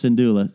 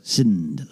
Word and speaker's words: Syndulla: Syndulla is your name. It's Syndulla. Syndulla.